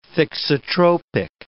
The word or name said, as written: thixotropic